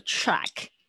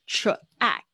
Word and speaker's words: track: 所以有时候不讲 platform 的时候，track 也要搞清楚到底是第几个轨道进来，火车在第几个对轨道进来，要记得这个 track。我特别要就是强调一下，大家对 tr 的发音，tr tr tr track track。嗯，这边有看到慧宇，早安，track。啊，不要念错了，这个 track track track，所以呢，a 的发音呢，记得要稍微向下压，舌头向下压一点，track。然后你的 ck 那个 k 的声音，不要念的太重，因为太重感觉后面好像多了一个字母。